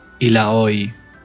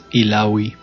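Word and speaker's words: Illaoi: Es Ilaoi, o como la pronuncian en inglés, Ilaoi.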